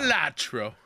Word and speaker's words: Balatro: How do you say the poker game everybody's talking about? – Balatro. – Balatro. Okay. – At least that's how I say it.